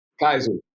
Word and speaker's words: Kaiser: Am I saying it right, Kelsey? – Kaiser. But don't trip. Nobody messed that up. – Kaiser, Oregon. I ain't gonna never mess that up again.